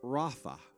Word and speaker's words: Rapha: Be still in the Hebrew is pronounced Rapha.